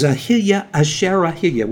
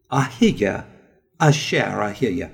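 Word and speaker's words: Ahiyah Asher Ahiyah (אהיה אשר אהיה): It is basically defined in another way in the Old Testament as well as the New Testament as Ahiyah Asher Ahiyah, which means, that's the Hebrew pronunciation, I am that I am. Ahiyah Asher Ahiyah.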